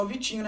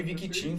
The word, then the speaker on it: Victinho: Era pra ser só Vitinho, né, mano? A pronúncia, mas aí uhum. a galera uhum. – chama de Viquitinho.